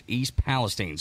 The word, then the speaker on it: Palestine: And I thought it was called Palestine, and then I got yelled at, and it's pronounced East Palestine.